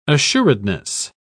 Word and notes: assuredness: British English

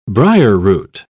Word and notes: briarroot: British English

